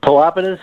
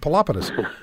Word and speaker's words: Pelopidas: How do you pronounce the name of your firm? – Pelopidas, or a simpler brand is First Rule Media. – All right. That's even better. But Pelopidas, I knew I'd put the emphasis in the wrong syllable, so better you do it than I.